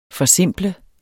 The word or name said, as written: FORSIMPLES